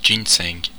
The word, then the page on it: ginsengs: France (Normandie); /ʒin.sεŋ/